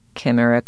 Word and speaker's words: chimera: Josephine, how do we pronounce chimera? Chimera. Okay, official.